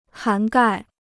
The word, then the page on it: 涵盖: hán gài